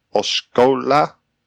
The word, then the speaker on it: Osceola: The hotel will be across from a Galaxy's Edge between Studio Drive and Osceola Parkway. How do you say that? – Osceola? – I think you got it.